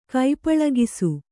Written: ಕೈಪಳಗಿಸು

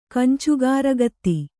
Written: ಕಂಚುಗಾರಗತ್ತಿ

